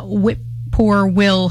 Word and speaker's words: whippoorwill: Thanks to the assiduous efforts of the local land trust over many years, a substantial amount of whippoorwill habitat is now protected from development. – Having some trouble with whippoorwill? – Is that how you say it? – Whippoorwill. It's a bird, yeah.